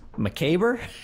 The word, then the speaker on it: macabre: This is a word, the way that I pronounced it was macabre. It's... – Oh boy. – It's a M-A-C-A-B-R-E. And I think that it's either pronounced now macabre or just macabre, and then the R-E can fuck off.